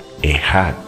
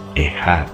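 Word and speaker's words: ehat (אחד): Ese es el significado de esa palabra, echat que se pronuncia ehat, O sea, la manera de copiarla es E-C-H-A-D, Echad. Se pronuncia Echad Denota una pluralidad,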